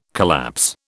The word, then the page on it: collapse: British English